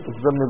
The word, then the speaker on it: الذم: أصحاب الكبائر مستحقون للذم، الذم بالذال مش بالذيل، مستحقون للذم والوعيد والعقاب في الآخرة وهم مؤمنون كامل الإيمان رغم كونهم معذبين في الآخرة.